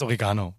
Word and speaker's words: Oregano: Das heißt Oregano. Wer was anderes sagt, weiß ich nicht.